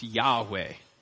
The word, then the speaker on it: Yahweh: The consensus now is that it's pronounced Yahweh.